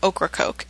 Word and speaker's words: Ocracoke: I happen to know that Laura is from Ocracoke, and that's how you pronounce it, because I've actually been there before.